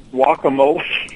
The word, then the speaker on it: guacamole: It's guacamole. Even the Mexicans say it that way.